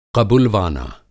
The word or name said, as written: क़बुलवाना